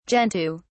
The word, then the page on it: gentoo: British English